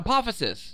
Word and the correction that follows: Apophis: Yeah, it's like Apophysis 90999 or some shit. Yeah. – No. – Apophis. I forgot how you say it.